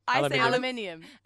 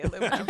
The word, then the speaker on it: aluminum: Aluminium. – No, I still say aluminum.